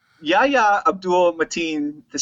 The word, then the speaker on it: Yahya: I think the guy's name is Yahya. – Is it really? – I mean, that's what it said. That's how I... How else could you pronounce Y-A-H-Y-A? Got to be Yahya Abdul Mateen.